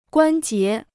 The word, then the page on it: 关节: guān jié